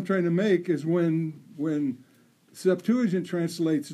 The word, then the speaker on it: Septuagint: Septuagint. – Septuagint. – Septuagint. – Septuagint.